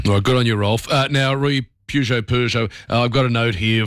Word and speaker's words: Peugeot: Peugeot, silent T there. In English, Peugeot, from French, Peugeot.